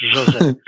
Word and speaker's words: José: Everyone says my name wrong. It's José.